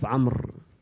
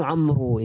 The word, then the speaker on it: Amr: For example, very famous name in Arab is Umar and Amr. Both famous. So both Ayn, Mim, Ra. How they differentiate? They put waw in the end of Amr.